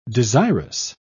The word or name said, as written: desirous